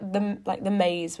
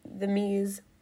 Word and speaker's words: The Mease: I am pronouncing the Mease correctly, am I? – Yeah. Yes. – Yeah, why? – We've had a few mispronunciations. – What's the worst one? Like, I assume like the Maze would be a big one, like a big mispronunciation.